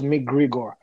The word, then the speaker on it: McGregor: I can't even know if I pronounce it right. I think it was McGregor. It's a brand that was whack, actually, but good for baseball stuff. – No, I think you got it right.